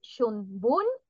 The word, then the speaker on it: Shunbun: no, perdón, el de primavera es Shunbun y el de equinoccio Shubun, que sí,